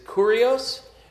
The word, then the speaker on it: kurios: The word that is translated Lord there is kurios. Just think curious, but pronounce it slightly differently.